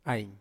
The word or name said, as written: ai